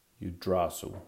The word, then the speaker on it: Yggdrasil: I'll call it Yggdrasil.